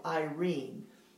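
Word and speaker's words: Irene (εἰρήνη): The word for peace that's used here is a Greek word called Irene, and it's spelled E-I-R-E-N-E.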